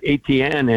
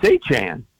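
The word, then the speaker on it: Etienne: And in his first press conference, you know, he says it's not ATN anymore. And I live not far away from a family of ATNs. They spell it the exact same way, said it that way. And he said, it's A-chan.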